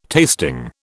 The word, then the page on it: tasting: British English